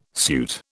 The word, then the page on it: suit: British English